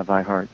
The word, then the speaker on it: Vi Hart: Oh, I didn't know that, because I know the connection between him and his daughter, V, or maybe she pronounces it Vi Hart. – Yeah, Vi Hart,